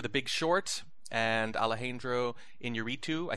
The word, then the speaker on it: Iñárritu: Alejandro Iñárritu. – Alejandro González Iñárritu. – See, you can roll the R's. I should just have you say all these for me. Yeah. Iñárritu for The Revenant.